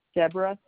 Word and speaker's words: Deborah: Deborah, D-E-B-O-R-A-H. – Okay, and what is that last name? – Martino, M-A-R-T-I-N-O.